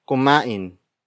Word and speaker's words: kumain: Word for word, it's kumain ng mabagal si Chris. Kumain ng Mabagal. C. Chris. Kumain. Nang. Mabagal. C. Chris. The first word is kumain. Kumain. Kumain. Kumain is spelled as K-U-M-A-I-N.